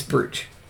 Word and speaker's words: brooch: Brooch. Isn't it brooch? – It's brooch.